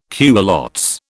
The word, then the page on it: culottes: British English